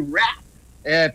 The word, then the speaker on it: Wrath: Oui, la colère des dieux, de Wrath of Khan. – R-A-T-H, oui, Wrath.